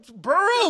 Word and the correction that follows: Bureau: Bureau. Like, do I gotta pronounce every little fucking shit? It helped. Keep going. It helped. – okay. and then what happened? – Better Business Bureau. Is that better? Yes. yes.